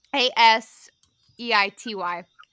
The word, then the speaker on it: aseity: Aseity, not aseity.